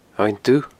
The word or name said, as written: Abhainn Dubh